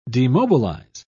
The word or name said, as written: demobilize